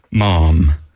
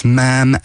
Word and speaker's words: ma'am: And I hope I remember this right, because it's always that debate of ma'am or mom, and they tell you not Liz. It's ma'am as in jam is how you say it.